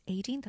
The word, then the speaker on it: Aideen: So being a former primary school teacher, I'll just be like, okay, so my name is Aideen. That's spelled A-I-D.